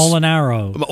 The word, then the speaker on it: Molinaro: She was Chachi's mother, and Al Malarino, of course. – Molinaro. – Oh, is it Molinaro? – I think so. – I've been pronouncing it wrong since the 70s.